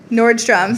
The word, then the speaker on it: Nordstrom: Nordstrom. Sorry. Stop pluralized. if you're saying an S on it, you're saying it wrong, y'all.